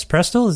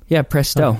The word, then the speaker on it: Prestel: It's out now via Prestel, I believe. Yes, Prestel? Is that the place? – Yeah, Prestel.